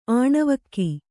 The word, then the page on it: ಆಣವಲಕ್ಕಿ: Kannada